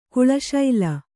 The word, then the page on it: ಕೇಳೀಶೈಲ: kēḷīśaila